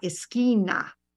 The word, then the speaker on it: esquina: On the corner is en la esquina.